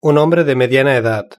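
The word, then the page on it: un hombre de mediana edad: European Spanish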